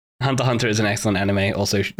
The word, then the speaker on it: Hunter x Hunter: You don't pronounce the X. Like Hunter X Hunter.